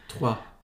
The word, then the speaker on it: trois: Trois.